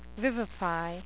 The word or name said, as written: vivify